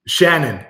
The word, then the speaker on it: Channon: Shannon, like an S.